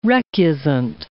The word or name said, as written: recusant